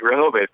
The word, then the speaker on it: Rehoboth: Okay, Rehoboth, Delaware. And I think I must have not said that correctly. – No, you did. Rehoboth, Delaware.